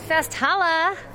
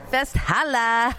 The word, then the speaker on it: Festhalle: Think of it like this Festhalle. – Festhalle. – Right? Give me a holla. – Festhalle. Perfect. You're good.